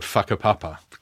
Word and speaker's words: whakapapa: Right at the center of it is there's a rather wonderful word called whakapapa, correct pronunciation, yeah.